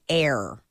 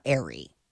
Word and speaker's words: Aire: I guess his name is Air. – Air instead of Wolf? I like Wolf. – And it's spelled A-I-R-E, but she's like, it's pronounced Air, not Airy.